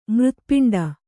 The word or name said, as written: ಮೂರ್ಧಪಿಂಡ